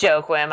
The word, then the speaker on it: Joachim: It's Joachim. I heard him say it. Is it really? – Yeah, I know. It sounds like it's Joaquin. It's spelled like it's Joaquin, but it's Joachim.